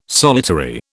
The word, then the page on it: solitary: British English